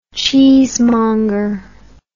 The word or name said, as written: cheesemonger